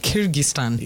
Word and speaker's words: Kyrgyzstan: Kyrgyzstan. Yes. – There we go. – It's those stands that are in Central Asia that we were shocked the other day. – Kazakhstan, Turkmenistan, Kyrgyzstan.